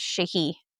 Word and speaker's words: sidhe: It's spelled S-I-D-H-E, but it's pronounced Shahi.